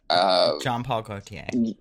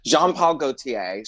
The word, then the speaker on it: Jean-Paul Gaultier: Jean-Paul Gaultier. – Jean-Paul Gaultier.